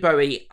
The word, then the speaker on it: Bowie: So Deneuve and Bowie Bowie rhymes with Zoe.